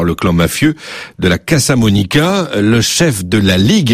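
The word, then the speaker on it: Casamonica: Pour commencer, dites-nous qui est ce clan des Casa Monica, hein, si je prononce bien, dont on a peu entendu parler jusqu'à présent. – Oui, vous avez parfaitement bien prononcé.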